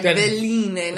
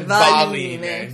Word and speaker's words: veline: And I might have mispronounced it wrong, and I think it is more Veline. – V-E-L. Mm-hmm. Than V... – Like a villainess, like Veline, not Valine. – V-A-L.